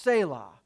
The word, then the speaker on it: Selah: There's an interesting word in many of the psalms pronounced Selah.